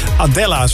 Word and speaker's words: Adela: je voorstellen aan Adela. – Hey Adela. – Nee, niet die Adela van de Jan Smit fangroep. Nee, dit is een Adela. Adela, zo spreek je het eigenlijk uit.